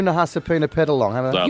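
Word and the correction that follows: Nahasapeemapetilon: You're saying it with an N for Nelly. It's M for Mary.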